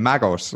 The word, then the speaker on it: Magoz: Porque los americanos como que, dicen magos como... – Sí, algo así. Magos, es eh, lo común, sí que parece un poco árabe, cosa que también me gusta.